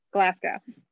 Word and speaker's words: Glasgow: Okay, there's so I just listened to a podcast and they said, say it like you're saying glass cow, but say it like fast. And that's Glasgow. How you say, yes, that's how you say it. – Glasgow.